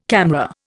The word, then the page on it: CAMRA: Indian